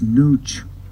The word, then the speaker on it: Nooch: And Utah got its name from that, but our name for ourselves, and we've called each other, is Nooch. Nooch. The proper grammar for that pronunciation is N-O-O-C-H. Nooch. Nooch. Not, you know, we spell it N-U-C-H, really.